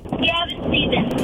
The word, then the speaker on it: Gavin Stevens: Gavin Stevens. – Okay. How do you spell that? – G-A-V-I-N-S-T-E-V-E-N-S.